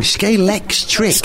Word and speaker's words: Scalextric: You're saying it wrong. Scalextric. – Scalextric. – That's right.